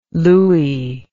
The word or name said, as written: louie